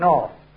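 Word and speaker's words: Knorr: About Nor. – Well, why don't you put Nor? – She's Nor. – No, how do you spell Nor? – N-O-R. – No. K-N-O-R. K, the K is silent, you see?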